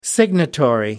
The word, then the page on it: signatory: American English